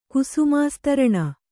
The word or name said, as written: ಕುಸುಮಾಸ್ತರಣ